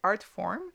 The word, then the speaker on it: Artphorm: Je vais commencer avec Artform, qui s'écrit A-R-T-P-H-O-R-M, qui est un jeune producteur de musique house ambient de 28 ans.